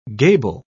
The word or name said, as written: gable